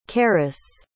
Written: charis

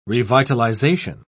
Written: revitalization

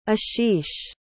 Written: ashish

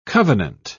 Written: covenant

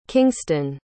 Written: kingston

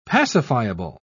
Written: pacifiable

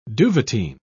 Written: duvetyn

